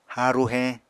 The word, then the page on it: haaru heñ: Apatani